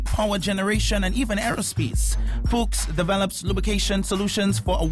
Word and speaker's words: Fuchs: That's F-U-C-H-S, pronounced Fuchs, is a global lubricant specialist with nearly 100 years of German engineering technology, research and development behind the brand.